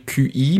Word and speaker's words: Qi: Ça s'écrit QI, mais ça se dit Qi,